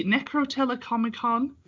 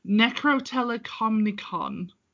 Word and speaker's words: Necrotelecomicon: most explicit of which is the Necrotelecomicon, or That's not how you say it. Hold on. I said Comic-Con at the end of that. Necrotelecomicon.